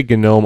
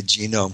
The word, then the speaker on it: GNOME: I say GNOME only because I talked to someone who, or actually more than one person, who works on the GNOME Foundation, and they say that's how it's pronounced.